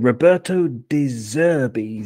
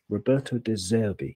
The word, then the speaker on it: Roberto De Zerbi: Roberto De Zerbi, Z-E-R-B-I. Roberto De Zerbi.